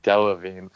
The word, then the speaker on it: Delevingne: How do you pronounce her last name? Is it Karen Devine? – Delevingne. – Delevingne. I can't help it.